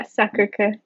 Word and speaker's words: Sacré-Cœur: Yeah, Sacré-Cœur.